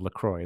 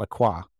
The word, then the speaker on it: LaCroix: It's the correct pronunciation of LaCroix. It's not LaCroix.